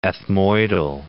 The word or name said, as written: ethmoidal